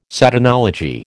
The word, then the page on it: Satanology: American English